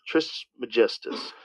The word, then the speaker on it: Trismegistus: Trismegistus.